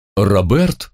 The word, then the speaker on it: Роберт: Роберт, с ударением на «э», Пантелеймонович Забайкальский-Бельский, ваш скромный проводник по суровым дебрям, ступеням и весям всего забытого людьми и богом некогда величественного храма.